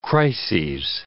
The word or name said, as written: crises